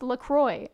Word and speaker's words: LaCroix: It's like LaCroix. You know that drink, the sparkling water that people just call LaCroix? Like, no. – That shit's LaCroix. Bienvenue à Montréal. There's an X at the end. And then the people that like made the drink, like the people, the brand, was like, no, it's pronounced LaCroix. And I was like, why are you trying to use a French word if you can't even say it right? – They're American.